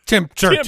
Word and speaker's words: temperature: I learned that temperature is supposed to have three syllables only. – Temperature.